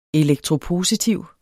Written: elektropositiv

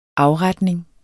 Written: AFRETNINGEN

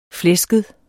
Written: flæsket

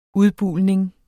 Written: udbulning

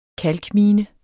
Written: kalkmine

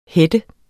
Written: header

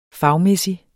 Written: fag

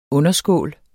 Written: underskål

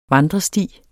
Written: vandresti

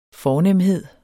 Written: FORNEMHEDEN

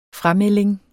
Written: FRAMELDING

